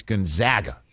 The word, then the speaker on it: Gonzaga: Okay, if you have that as your champion, you need to know it's Gonzaga. – I know, I always say that wrong. – I know you're from New Jersey. You're a Gonzaga, Nevada guy. It's Gonzaga and Nevada.